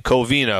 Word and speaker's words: Covina: Raju in Covina, if I'm pronouncing that right. – Yeah, you got it right, man.